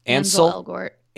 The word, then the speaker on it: Ansel Elgort: Ansel Elgort.